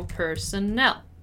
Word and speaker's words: personnel: Notice that personnel has a strong nel sound, so personnel.